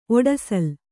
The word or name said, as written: ಒಡಸಲ್